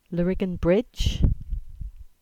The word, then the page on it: Lariggan Bridge: English